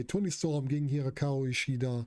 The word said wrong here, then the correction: Hikaru Shida: Ich kann das Wort einfach nicht aussprechen, diesen Namen. Hikaru Shida.